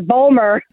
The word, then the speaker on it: Baltimore: Well, I wonder if the rest of the country is like, why don't they pronounce the T in Baltimore? – Oh, you know what I'm saying? And we're like, because we don't. – It's Bulmer.